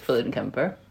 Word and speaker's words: Fuellenkemper: I, like you, have a unpronounceable last name, so it's Fuellenkemper.